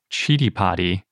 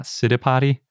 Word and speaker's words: Citipati: By the way, little side note, I found the Sanskrit pronunciation guide for Chidipati, and that seems like it's the correct way to say it and not Citipati or Citipati, which is especially confusing now because we have Citipes and Chidipati, which are both spelled C-I-T-I.